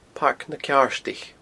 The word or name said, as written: Pairc na Ceardaich